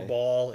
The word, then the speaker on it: Baal: Or Ball. It's B-A-A-L. I like pronouncing it Baal because it sounds different than just Ball.